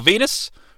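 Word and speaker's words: Venis: We're going to see Kenny Kenny Shamrock taking on Val Venus, rhymes with anus.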